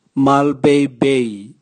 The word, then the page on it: malebeibei: Raga